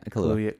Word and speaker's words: Kaluuya: I mean Kaluuya. I hope I'm saying that right. – Yeah, Kaluuya.